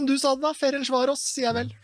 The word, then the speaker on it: Ferencváros: Hvordan uttaler du egentlig Ferencváros? For det skal leses som Ferencváros, ifølge Mathias.